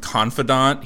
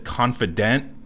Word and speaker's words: confidant: Although that just kind of annoyed me because Gene screwed up the pronunciation of confidant. He said confident, said the Undertaker's confident, Paul Bearer.